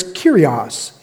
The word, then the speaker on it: kairos: There's a second word in the Greek language for time, and it's kairos. It's kairos.